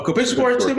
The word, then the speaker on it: compensatory: Yeah, a compensatory pick for Nick Foles. – A what pick? – Now y'all gonna make me say it wrong. Compensatory pick. – Yes! – You got it.